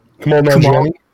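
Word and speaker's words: Kumail Nanjiani: Kamal Nanjiani. I don't know how you say his name. Sorry. – Kamal Nanjiani.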